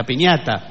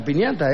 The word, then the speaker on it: piñata: colgar la piñota? – La piñata. – ¿La piñata es? – Sí, la piñata. – ¿No se dice piñota? – No.